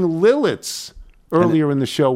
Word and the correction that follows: Lititz: It's Lititz.